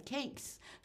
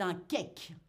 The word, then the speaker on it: cake: Et eh oui, c'est un mot anglais, mais vous le savez peut-être, en français, on aime prononcer les mots anglais à la française. On ne va pas dire cakes. Non, c'est un cake, un cake salé.